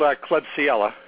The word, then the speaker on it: Klebsiella: It's called Klebsiella, and it's spelled K-L-E-B-S-I-E-L-L-A. The last word is pneumonia, P-N-E-U-M-O-N-I-A-E.